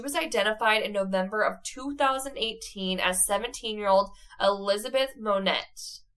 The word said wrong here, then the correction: Lamotte: Lamotte. Oh my goodness, I butcher these names so bad. Lamotte? L-A-M-O-T-T-E. Her DNA was obtained by New Hampshire police in 2017, and results were concluded that the victim was Elizabeth.